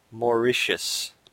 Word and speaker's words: Mauritius: He says it's pronounced more like Mauritius.